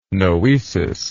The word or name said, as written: noesis